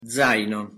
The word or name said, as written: zaino